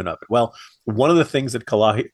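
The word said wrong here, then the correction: Cahaly: I'm sorry, Kahali, I keep, I keep, you know, mispronouncing that.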